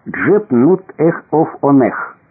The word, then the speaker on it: Джепнутэфонх: У нас на письме это имя имело бы такой вид: «Джепнутэфонх», что проворными устами детей Египта произносилось как «Джепнутэхофонех» с нёбным звуком «ха» в конце.